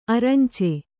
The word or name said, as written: ಅರಂಚೆ